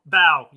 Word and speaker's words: Bao: B-A-O. – Bow. Yeah, bow. – Oh my god, it wasn't even close.